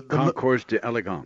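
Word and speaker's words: Concours d'Élégance: How do you say it? The Concourse d'Elegance. – D'Elegance. – Yes.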